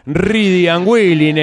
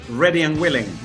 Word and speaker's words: Ready and Willing: Disco que se llamaba, vamos a decirlo como se decían los cassés en esa época, Reedy and Willing era el nombre, ¿no? De esa manera se pronunciaba. Nada de andar diciendo nada de inglés, ¿no? Reedy and Willing. – Lo digo ahora como lo dirían en Martínez. – Sí, Reedy and Willing. – Ready and Willing.